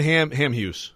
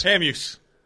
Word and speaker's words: Hamhuis: I seriously still can't say his name right. So all last year, I'm like, I'm just not going to say it. Dan Hamhuis. – That's good enough.